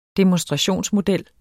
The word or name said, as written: demonstrationsmodel